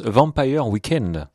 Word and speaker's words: Vampire Weekend: Merci beaucoup Nicolas Chatin, et vu que vous avez un bel accent en anglais, je vous précise que le groupe que vous avez cité se prononce Vampire Weekend.